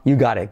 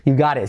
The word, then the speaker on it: you got it: You got it. Fíjate que la T casi última no se pronuncia. Lo mismo T como R suave y como E y la T última casi no se dice.